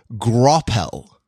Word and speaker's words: graupel: which I've learned is called grapple.